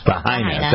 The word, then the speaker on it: Lahaina: Lahaina.